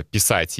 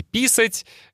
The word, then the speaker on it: писать: И кажется, Э, писать и писать — это такие похожие слова, только меняется ударение.